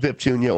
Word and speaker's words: Biptunia: I went out and bought the band name, which is Biptunia, B-I-P-T-U-N-I-A. It's pronounced Biptunia or Biptunia. Both are acceptable.